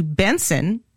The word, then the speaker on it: BNXN: First, here's Finesse by Feels featuring singer Buju, who has recently changed his name to Benson, and it's spelled B-N-X-N, by the way.